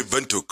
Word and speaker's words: Windhoek: And I'll say right off the bat that Windhoek, I don't know how you guys pronounce it, Windhoek. – You're very close. So we just say Windhoek. – Windhoek. – It's almost the same.